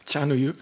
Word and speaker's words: chanoyu: Chanoyu.